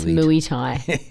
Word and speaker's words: Muay Thai: Say it properly. – What do you call it? – It's Muay Thai.